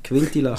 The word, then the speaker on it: Quintilla: Wer wohl meiner Meinung nach eine dicke Saison wird machen, ist der, und jetzt kommt Jordi Quintilla. – Quintilla. Ist das richtig ausgesprochen? – Ja, ich habe eine Spruchnachricht bekommen von einem anderen Podcast aus Basel.